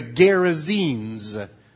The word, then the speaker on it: Gerasenes: Honey, it's pronounced Gerasen.